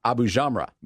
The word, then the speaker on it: Abujamra: Lina uh, Abujamra.